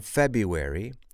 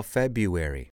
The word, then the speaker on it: February: What is strange about that word is that the first R is silent. How do you spell February? F-E-B-R-U-A-R-Y.